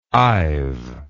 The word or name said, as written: I've